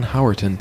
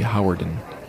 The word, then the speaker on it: Howerton: I say Glenn Howerton, but I hear people say Howardin.